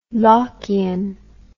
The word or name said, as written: lockian